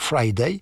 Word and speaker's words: Fridayy: Il a trouvé une très belle solution pour qu'on puisse le retrouver lorsqu'on veut avoir des infos sur Friday. Il a rajouté un Y, donc ça s'écrit F-R-I-D-A-Y-Y. Donc en principe, il faut prononcer Friday.